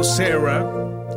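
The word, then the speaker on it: Sara: Escuchamos a Fleetwood Mac con Sarah, o Sarah, que sería la pronunciación en inglés, ¿no?, de esta canción de 79.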